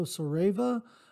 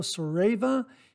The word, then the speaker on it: Saraiva: This is an artisan named Fernando Sareva. I believe that's how he pronounces his last name. S-A-R-A-I-V-A. It's Fernando Sareva.